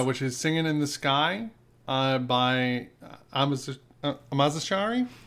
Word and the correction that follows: Amazarashi: Amazarashi. – We're sorry that we butchered that.